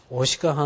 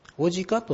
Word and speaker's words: 牡鹿: はい、男の鹿と書いて男鹿半島、これ秋田県、まあ、これからやりますけど、はい、そして、 25 番の方ですね、こちらがオスの鹿と書いてオシカ半島、オジカと発音する人もあるようですが、オシカまたはオジカ半島ですね、まあ、読み方はガイド試験には、まあ、基本的に出ないです。